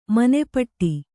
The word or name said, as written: ಮನೆಪಾಠ